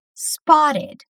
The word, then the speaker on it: spotted: Spotted. Essa é outra palavra que é escrita com T, mas que nós vamos pronunciar como R.